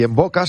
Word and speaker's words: Mvoca: Y en Boca se escribe M-V-O-C-A. En Boca. Primera consulta, estudio gratuito. Está en Velázquez 96. M-V-O-C-A.